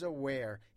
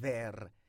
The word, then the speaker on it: where: دقت داشته باشیم بر تلفظ where. هیچ وقت نگین where. W در زبان انگلیسی V تلفظ نمیشه.